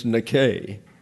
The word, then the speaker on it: Nike: This is not an epsilon, but an eta, and it's pronounced like a long A, and they don't do that in the tennis shoe company. So it's Nike is the word, and that means victory, he who overcomes.